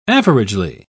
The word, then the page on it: averagely: British English